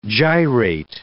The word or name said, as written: gyrate